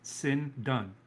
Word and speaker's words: sindone: And also, it's interesting, the Greek word for linen, it might be linen cloth, is phonetically pronounced sin done. S-I-N-D-O-N-E.